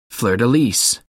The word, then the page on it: fleur de lis: American English